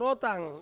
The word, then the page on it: rotang: Ansus